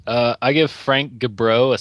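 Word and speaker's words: Gibeau: But So there was a story that came out that, is it Frank Gabo? Is that how you say his name, I guess? President of... – Frank Gabro.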